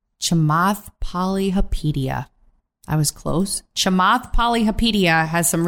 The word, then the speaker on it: Chamath Palihapitiya: I think it's Kamath Palihapitiya. Let me see. I'm going to do this with you. – Chamath Palihapitiya.